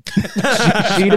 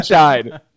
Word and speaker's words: Scheid: I said it wrong earlier. Drew Scheid. – Oh, no. You're gold. Here's the thing. I never correct people because as long as you're that's still my name. Scheid and Scheid.